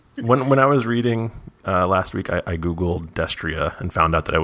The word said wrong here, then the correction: Destrier: That's how you say it? Oh. It's Destrier.